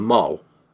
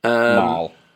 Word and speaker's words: Mal: And I didn't know she's called Mal, as in M-A-L. I thought she was called Moll. Like Molly, Moll, like a mole, like in a gangster film, like a mole.